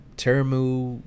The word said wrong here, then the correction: Temuera: Um, Temuera Morrison.